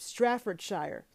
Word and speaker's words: Staffordshire: I think they say it's pronounced Stratfordshire. Ugh, God, I fucked that up. Staffordshire, Fenton, Staffordshire. Anyways,